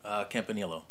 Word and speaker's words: Campaniello: Campaniello.